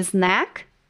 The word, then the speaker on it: snack: A primeira é tomar cuidado para não confundir a pronúncia de snack com snake, pois snake significa cobra. Portanto, muita atenção. Snack, lanche. Snake, cobra.